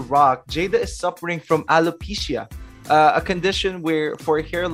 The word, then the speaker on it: alopecia: I think you're saying it correctly. It's alopecia. And I know a lot of people who suffer from this.